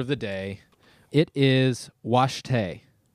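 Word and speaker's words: wašté: It's spelled waste for some reason, but it is pronounced Washte.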